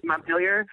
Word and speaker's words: Montpelier: Montpelier. Montpelier. That's how Americans pronounce it. Yes. – Yeah, but it's Montpellier is the French Yes.